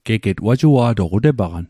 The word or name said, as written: wajua'toq